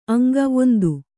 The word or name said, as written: ಅಂಗ